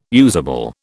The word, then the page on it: usable: British English